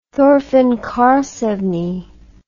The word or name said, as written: Thorfinn Karlsefni